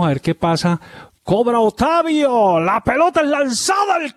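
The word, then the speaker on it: Otávio: Pero es que se dice así, porque allá está en portugués así, Otavio. Sin sí.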